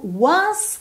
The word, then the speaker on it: once: Só mesmo once que você tem que prestar atenção que o E final não é pronunciado.